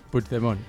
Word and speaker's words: Puigdemont: Puigdemont, se pronuncia. – Puigdemont.